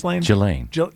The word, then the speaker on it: Ghislaine: Because that, how do you say her name? Ghislaine? – Jelaine. – Is it Jelaine? – Mm-hmm. We don't know. – Somebody. I'll say Jelaine.